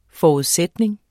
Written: forudsætning

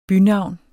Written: bynavn

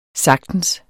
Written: sagtens

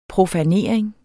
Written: profanering

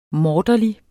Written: morderlig

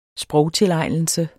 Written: sprogtilegnelse

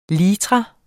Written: litra

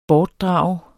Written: bortdrage

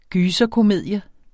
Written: gyserkomedie

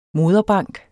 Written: moderbank

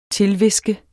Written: TILHVISKET

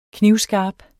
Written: knivskarp

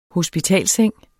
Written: hospitalsseng

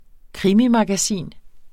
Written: krimimagasin

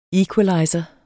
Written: equalizer

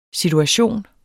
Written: situation

